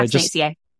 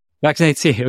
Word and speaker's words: VaccinateCA: And it's called Vaccinate California, I think is how you pronounce it. – So Vaccinate CA. – Vaccinate CA. Okay.